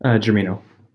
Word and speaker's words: Germino: Am I pronouncing your last name right, Kevin? – Germino. – Germino. That is much more Italian.